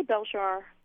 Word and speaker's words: Beljar: I say Beljar. Okay. A little stress on the first syllable more, but it's fine.